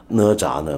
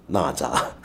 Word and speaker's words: 哪吒: 那么最近有朋友说我昨天的节目呢，哪吒呢被我读成了那吒，然后跟着门槛呢，我总是把它读成门栏，这是怎么回事呢？